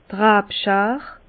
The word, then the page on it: d' Rabschar: Bas-Rhin; Herrlisheim